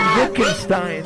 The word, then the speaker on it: Wittgenstein: See, I knew how to pronounce the W in that. Wittgenstein.